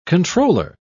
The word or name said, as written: controller